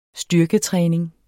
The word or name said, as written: styrketræning